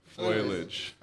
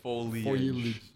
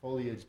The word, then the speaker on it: foliage: Foliage. – Foilage. – I can't even though I try to say it wrong, I can't. – Foliage. Man, yeah. – Foliage, people.